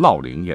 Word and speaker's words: 乐陵: 居住在大概今天山东乐陵市附近啊，你你也可以按照历史的传承读成烙陵也对啊。